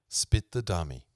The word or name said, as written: spit the dummy